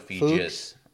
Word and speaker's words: Feges: Fegis. – Rhymes with Regis. – Fegis? – If you can't pronounce it, it's got to be good. – And that's his new tagline. That's his new slogan. Love it. Yeah. – Good job, Patrick, Aaron, whoever came up with that. That was smart. So yes, Patrick Fegis, F-E-G-E-S, pronounced Fegis.